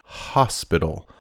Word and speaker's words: hospital: Pronunciation, hospital. Hospital. Now, we talk about animal hospitals. That pronunciation has a strong aspirated H there. Hospital. Similar to that little animal that people have as pets, a hamster. Hamster.